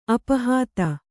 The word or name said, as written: ಅಪಹತ